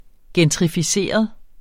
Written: gentrificeret

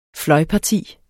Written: fløjparti

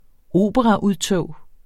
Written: operaudtog

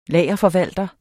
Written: lagerforvalter

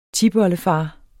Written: tipoldefar

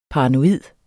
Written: paranoid

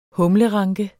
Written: humleranke

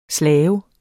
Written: slave

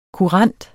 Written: kurant

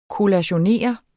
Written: kollationere

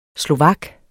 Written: slovak

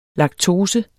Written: laktose

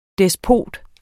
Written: despot

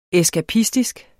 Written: eskapistisk

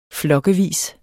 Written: flokkevis

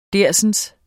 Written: dersens

